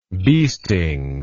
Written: biestings

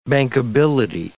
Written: bankability

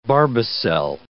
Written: barbicel

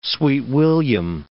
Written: sweet william

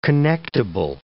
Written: connectible

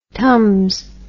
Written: tums

